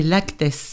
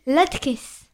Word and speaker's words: latkes: D'abord des laktes. – Non, des latkes.